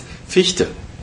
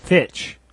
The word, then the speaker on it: Fichte: I know that's difficult for an American to pronounce, but it's Fichte, and it's George Hegel.